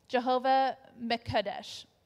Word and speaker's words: Jehovah Mekadesh: Jehovah Mekadesh. I know it doesn't look like that, but that's how it says to pronounce it.